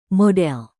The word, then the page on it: model: Indonesian